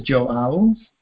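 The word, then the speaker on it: João: Yeah, Joao. Like, wow, with a J.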